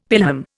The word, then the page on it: pelham: Indian